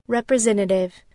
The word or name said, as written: representative